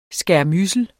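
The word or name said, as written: SKÆRMYDSELS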